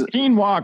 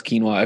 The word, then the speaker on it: quinoa: He still can't pronounce quinoa. If you ask him, like if you say, Dad, what does this say? He'll he says quino. Quino.